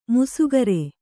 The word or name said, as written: ಮೀಸೆಗಾರ